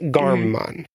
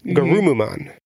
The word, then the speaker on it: Garurumon: It's not Garmumon. It's Garumumon. So it's Garurumon, but with the ru replaced with a mu. So it's Garumumon, which would also be plenty.